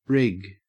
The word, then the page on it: rig: Australian English